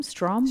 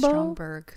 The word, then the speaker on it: Stromberg: Surprise, surprise, is, how do you say the villain's name? Strombo? – Stromberg.